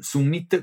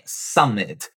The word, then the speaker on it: summit: Porém, a pronúncia dela não é summit, como em inglês, mas summit, com o U bem italianizado.